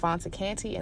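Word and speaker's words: Fanta Kante: And you can also follow me on Facebook at Fonta Kante, and that is spelled F-A-N-T-A, last name K-A-N-T-E.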